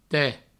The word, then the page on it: te: provençal